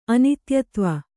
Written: ಅನಿತ್ಯತ್ವ

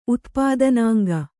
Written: ಉತ್ಪಾದನಾಂಗ

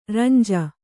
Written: ರಂಜೆ

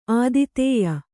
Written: ಆತಿಥೇಯಿ

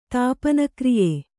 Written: ತಾಪನಕ್ರಿಯೆ

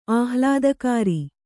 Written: ಆಹ್ಲಾದಕರ

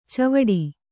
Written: ಚಾವಡಿ

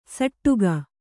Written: ಸಟ್ಟುಗ

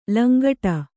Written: ಲಂಗಡ

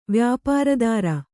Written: ವ್ಯಾಪಾರದಾರ

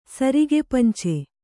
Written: ಸರಿಗೆಪಂಚೆ

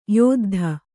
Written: ಯುದ್ಧ

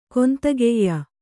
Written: ಕೊಂತಗೆಯ್ಯ

